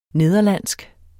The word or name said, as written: nederlandsk